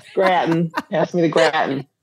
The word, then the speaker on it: gratin: Do you pronounce it gratin? Do you lean into the French pronunciation? – Of course I do, gratin.